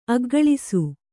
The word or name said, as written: ಅಗ್ಗಳಿಸು